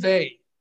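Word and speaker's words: Weil: Simone Vey, Vey spelled with a W, EIL, Andrew.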